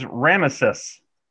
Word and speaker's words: Ramesses: This is Ramesses. Ramesses. See that? Three syllables there.